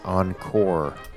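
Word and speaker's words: Encorps: The group, you know, they would perform together outside of Disney parks sometimes, and they were called Encore, spelled C-O-R-P-S, Encore, with a silent P-S, because Magic Kingdom Corps with a K, let's just make the silent P-S's roll at the end of Encore.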